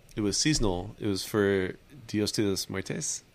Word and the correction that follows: Muertos: I said that wrong, but it's okay. Uh, Dia de los Muertes. Muertos. Yeah, muertos. Sorry. Oh, muertos.